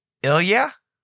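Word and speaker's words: Elyria: Elira Catholic is, excuse me, not Elira. Um, hmm. Ilya Catholic, I think that's how you say it. – It's not Lyra.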